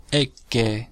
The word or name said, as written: ecce